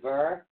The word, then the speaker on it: Berkana: Now, Burkina is B-E-R-K-A-N-A, but it is pronounced B-E-R-K-A-H-N-A-N. It's Burkanan. Burkanan.